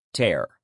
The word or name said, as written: tear